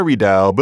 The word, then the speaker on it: redoubt: But if you read about them, you know the word redoubt, and you know that it's spelled R-E-D-O-U-B-T. Well, it's not a redoubt, but it's a redoubt. Somebody stuck the B in because they thought that the doubt in redoubt was related to doubt, as in being unsure, that it comes from a completely different source.